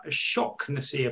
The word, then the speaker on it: Shaughnessy: Christian Sadie, Connor Shocknessy, apparently is how it's pronounced. Shock. – Oh, really? – Apparently. Okay. I'd say Shaughnessy, personally, but, you know.